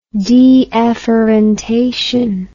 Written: deafferented

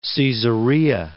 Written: Caesarea

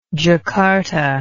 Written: jacarta

